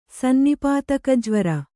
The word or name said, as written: ಸನ್ನಿಪಾತಕಜ್ವರ